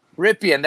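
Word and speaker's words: Rypien: Well, they're going with Brett Ripien. – Ripien, there you go. That's how you say it.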